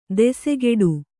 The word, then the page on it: ದೆಸೆಗೆಡು: desegeḍu